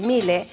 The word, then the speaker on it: Miele: que se escribe Miele, pero es Miele,